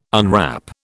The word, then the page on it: unwrap: British English